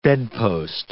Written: bedpost

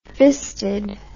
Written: fisted

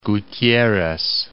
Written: Tuxtla Gutierrez

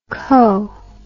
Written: co

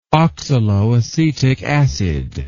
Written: oxaloacetic acid

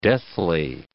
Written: deathly

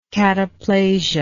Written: cataplastic

